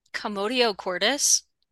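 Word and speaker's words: commotio cordis: Commodio cordis. – Commodio cordis, not the jab that's causing athletes to collapse.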